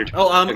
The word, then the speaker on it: Tomasi: So it's Tomasi. That's how you say it, right? – Yeah. Peter Tomasi. Okay. That's how I say it.